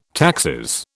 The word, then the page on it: taxis: British English